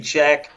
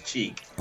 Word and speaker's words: Cheek: Somebody say Check, but it's Cheek.